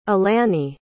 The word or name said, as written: elani